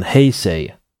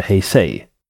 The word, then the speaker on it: Heisei: Och nu så lever vi i eran Heisei.